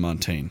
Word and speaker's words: Montaigne: Not like it's spelled like Devin Montaigne, who doesn't listen to the podcast.